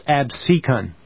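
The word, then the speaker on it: Absecon: It's pronounced Abseken.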